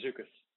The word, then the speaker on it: Zoukis: Yes, sir, Zukas.